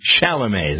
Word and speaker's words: Chalamet: The question is, did Gomez really tell her that Kylie Jenner wouldn't let her take a picture with Timothy Chalamet.